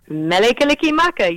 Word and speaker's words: Mele Kalikimaka: Mele Kalikimaka. – Mele Kalikimaka, you got it.